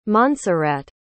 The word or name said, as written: monserrat